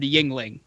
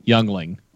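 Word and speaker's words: Yuengling: Everyone, now, although he butchered Yingling. – Yes. Youngling. – He called it Youngling. And I went, nope.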